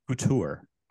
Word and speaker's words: couture: Couture.